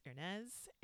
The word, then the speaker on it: Hernease: Just put in my first name, HERNEASE, pronounced Ernez.